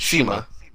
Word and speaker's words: Cima: It's Shima. Shima. – Shima? Okay. – I think it's spelled like C-I-M-A, but it's pronounced Shima. – Yeah. That throws me off. So it's pronounced like S-H-I-M-A, Shima? – Pretty much, yeah.